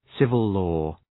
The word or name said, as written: civil-law